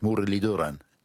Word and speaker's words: Mudaliduran: Yes, the TH is a D, which is a dental T with, I think, your tongue touching your teeth, Mudaliduran. Did I sound roughly right? – That's right. Oh, beautiful. You've pronounced it perfectly.